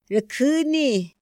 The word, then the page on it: ra kjüni: Otomí